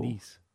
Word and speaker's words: gneiss: Do you remember how to pronounce the word G-N-E-I-S-S? – Noose? – No. – Nice. – No. – Nice. Can you spell that again? – What is it? – Nice. – It's nice. – Can you spell that again? – G-N-E-I-S-S.